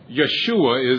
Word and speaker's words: Yeshua: Yeshua is its pronunciation in Hebrew.